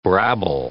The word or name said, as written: brabble